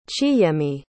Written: chiyemi

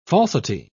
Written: falsity

